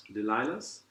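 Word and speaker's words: Delilah's: Das ist ein Limited Release von der Compass Box und das heißt, wie spricht man das aus? Delilahs? – Delilahs.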